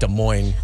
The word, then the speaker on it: Des Moines: I think the S is silent on there, so I think it's just Des Moines.